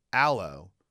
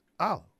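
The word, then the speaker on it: Alo: He goes, why do you always say Jocelyn's last name wrong? I was like, what do you mean? He goes, and then he did the exact pronunciation of it. Jocelyn Pumihana, you know, the full name. He goes, you don't have to share the whole thing. He goes, but it's Allo. I'm like, no, it's Allo.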